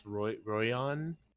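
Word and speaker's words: Roion: A lot of too many vowels in this one, but I'm going for Rowian. Rowian. – Rowian, okay. Yeah. How do they say this, and how would you like to spell it? – So it's Royon. Ah. I believe that's correct, Royon.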